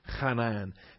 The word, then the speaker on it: chanan: All right, the first term is chanan, C-H-A-N-A-N, chanan. And this will be good during allergy season to pronounce the chith letter because I've got plenty of extra phlegm back there in my throat.